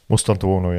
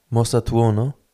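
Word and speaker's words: Mastantuono: Mostantuono, ja. – Mostantuno? – Tuono. – Mostantuono? – Ja.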